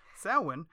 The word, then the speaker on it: Samhain: And it's actually spelled way weird, but it's pronounced Samhain. There's an M in there.